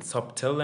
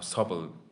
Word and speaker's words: subtle: همین این سابتل هم نیست بکنم سابل تلفظ میشه درسته تو زبانت بهتره